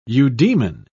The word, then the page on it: eudemon: British English